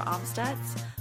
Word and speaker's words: Amstutz: And you can find me on all social media platforms at Sandra Amstutz.